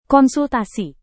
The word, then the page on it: konsultasi: indonesian